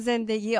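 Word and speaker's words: زندگی: Can I say Zen, Zendegi, and Azadi? I don't know if I said it right. – You said it correctly. Zen, Zendegi, Azadi.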